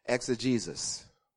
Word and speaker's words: exegesis: Secondly, you need good exegesis, which is not spelled X. X-A-Jesus. No, we're not trying to mock out Jesus. It is, but that's how it's pronounced. Exegesis. E-X-E-G-E-S-I-S.